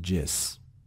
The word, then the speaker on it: just: Just تلفظ میشه جس. Just غلط آقا، نه درسته. ولی تو صحبت کردن میگن جس.